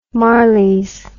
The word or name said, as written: Marleys